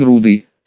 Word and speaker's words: рудый: «Какой он рудый!» С ударением на первый слог сказал Возгори, потянулся пальцами к Сагару.